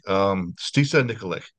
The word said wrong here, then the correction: Stasa: Stasha, I am so sorry. – Yeah, right. I apologize. I apologize. – Sorry, no Stasha Nikolic.